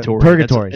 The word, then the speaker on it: purgatory: Purgatory. – It's a hard G.